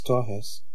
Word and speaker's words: Torres: The piano solo was Fabio Torres, or pronounced in Portuguese, Torres, and Paolo Paulelli was playing six-string bass, and he did a solo, too.